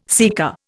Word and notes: sika: Indian

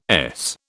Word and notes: Es: British English

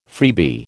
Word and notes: freebie: American English

